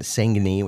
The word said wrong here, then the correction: Saignée: which I'm sure I'm pronouncing wrong? – Pretty close. I think it's a Saignée.